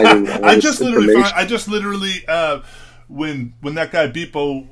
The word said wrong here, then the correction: Beeple: I know I've said his name wrong, but when it looks like Beeple.